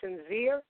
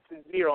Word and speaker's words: Synzere: And the other mixtape you should definitely check out is from Sinzeer, and that's spelled S-Y-N-Z-E-R-E.